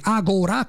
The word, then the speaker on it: Agora: Agora con l'accento sulla A del professor Dorsi,